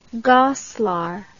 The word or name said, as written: Goslar